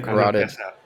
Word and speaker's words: carotid: Oh my God, how do you pronounce the artery? Cardioid? – Cardioid? – Yes. Yes, his cardioid artery, like, burst. – Okay, I can't. – That was the carotid. – Carotid!